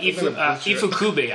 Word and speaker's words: Ifukube: His name's Inafune, I believe. I could be, if I'm mispronouncing it, correct me. – I'm sorry. Can you pause that? I can't remember. – Akira Ifukube. I was completely wrong on the first one. Akira Ifukube.